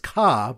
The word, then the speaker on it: car: All right, so now we go to a Simfer Chafalv, and the Simfer Chafalv is a car, but we use a car that's pronounced ka by Bostonians, people from Boston.